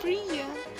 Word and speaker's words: Priya: I'm also known as Priya. This Priya is my nickname and it's really easy to pronounce,